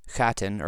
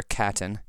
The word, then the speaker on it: Chattan: Starting off with actual Chattan or Catan, it's spelled with a CH in Gaelic, that's always a guttural sound.